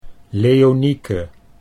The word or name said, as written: Leonieke